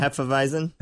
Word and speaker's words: Hefeweizen: Apparently, they have, you have to help me with this pronunciation. Is it Hefeweizen? – Yeah. Hefeweizen.